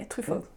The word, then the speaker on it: Truffaut: Truffaut, like that kind of, you know. – Yeah. – Did I say that right? – Yeah, Truffaut.